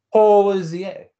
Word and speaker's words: Hozier: You were not doing the French pronunciation of it. – Hozier.